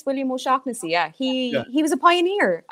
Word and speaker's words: O'Shaughnessy: But like cannabis was introduced to the Western world by an Irish physician named O'Shack Hennessy. Now, I believe he pronounced it. You're right, He pronounced it O'Shaughnessy, not O'Shack Hennessy. That's just ridiculous.